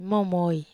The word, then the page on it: momoi: wamesa